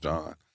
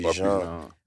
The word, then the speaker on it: Jean: I always thought that when it was Jean and people never said it right, I always thought it was just John. – Nah, it'd be Jean.